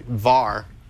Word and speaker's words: WARR: The team from Munich University, VAR... Even though it's spelled WARR, theirs went up to 200 miles an hour, just like 201 point something.